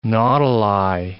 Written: nautili

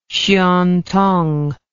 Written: xuantong

